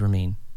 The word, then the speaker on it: Ramin: T-A-D-H-G. So. – I think you spelled it wrong. – So, your name is Ramin. – Correct.